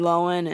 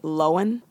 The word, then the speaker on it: Lohan: You could have pulled a Lindsay Lohan and shown everyone, flashed everyone your business. Yeah, I love the fact that she says Lindsay Lohan.